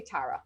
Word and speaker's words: Tara: I know it's me, but I say Tara.